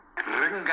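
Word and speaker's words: Gringa: You're saying Kringa, like crinkles or something. It's Kringa. – I actually pronounce it wrong. It's with a G. I sound like I pronounce it with a C.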